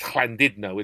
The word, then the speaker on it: Llandudno: And yeah, I ended up in a pub in Llandudno, as you'd call it if we were English, but in North Wales it's Llandudno, isn't it, with the two L's and the U pronounced as an I.